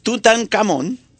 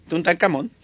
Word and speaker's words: Tutankamón: Tutankamón. ¿Lo dije bien? – Sí. – Ok. Tutankamón.